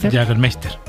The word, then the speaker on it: Jägermeister: ¿Cómo se pronuncia esto? – Jägermeister.